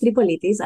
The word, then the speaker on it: Tripolitis: And I hope I pronounced your last name right. – Yeah, sure. It is Tripolitis.